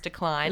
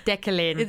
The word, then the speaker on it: Deklyne: but it's spelled D-E-K-L-E-Y-N, but they've made it very clear across all of their social media channels that it is pronounced Decline. – It looks like Declan. – Yeah, it's like Declan.